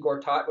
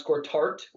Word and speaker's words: Gortat: But it was like, well, the reason for some reason I was reminded of Marcy and Gortat, which he would pronounce Gortart, which made no sense.